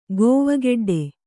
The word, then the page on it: ಕವೆಕಡ್ಡಿ: kavekaḍḍi